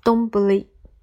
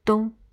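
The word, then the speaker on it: どんぶり: 它的发音是东不利。如果跟着某个名词之后，就简省的念为冬，指一碗有碗盖的白米饭，饭上铺着菜的食品。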